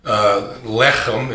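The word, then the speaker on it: Lechem (לחם): The word Beit, or B-E-T-H, means house. The word Lechem, Lechem is the way they would pronounce it, means bread, house of bread.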